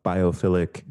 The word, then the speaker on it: biophilic: I enjoy that you talk about biophilic design. Did I say that right? – You said it perfectly right.